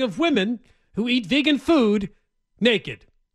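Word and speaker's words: Füde: F-U-D-E with the two little dots over the U like it's German. – Yeah. – They want you to pronounce it food, though. Don't say feud, like a feud between two people.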